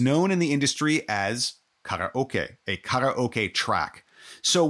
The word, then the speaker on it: karaoke: Well, so karaoke, it's actually pronounced karaoke in Japanese. Kara meaning empty and oke meaning orchestra.